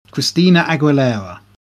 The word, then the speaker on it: Christina Aguilera: And he worked with artists such as No Doubt, Christiana Aguilera, Agu- Aguilera. I can never say her name. Christina Aguilera.